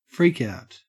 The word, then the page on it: freakout: Australian English